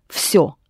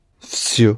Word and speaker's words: всё: But when you pronounce it in this word, всё, it becomes F. Just like it does at the end of готов. So, всё? – Всё.